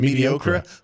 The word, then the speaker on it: mediocre: But if you look at the way it's spelled, it's not like C-E-R, it's C-R-E. So it is easy to see somebody saying mediocre.